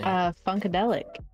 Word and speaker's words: Funkadelic: Funkadelic.